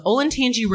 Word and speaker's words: Olentangy: The gates themselves are comprised of a drainage culvert carrying a stream from Glen Echo Park to the Olentangy River.